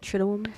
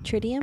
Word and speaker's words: Triduum: It's a Latin word, and it means basically the 3 days. I would always say the Triduum. – Okay. – Triduum. – Triduum. – But you could say Tritium.